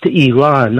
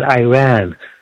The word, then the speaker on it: Iran: I was there, guest of yours from Idaho, it's pronounced Iran, not Iran. Like, we don't say Italy, we say Italia.